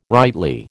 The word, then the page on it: rightly: American English